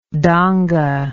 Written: donga

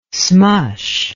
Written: smush

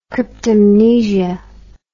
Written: cryptomnesia

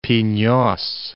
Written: Pinios

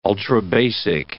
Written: ultrabasic

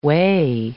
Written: whey

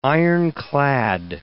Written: ironclad

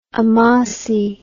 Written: amasi

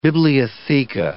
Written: bibliotheca

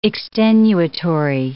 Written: extenuatory

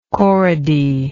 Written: corrody

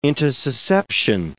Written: intussusception

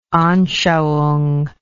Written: anschauung

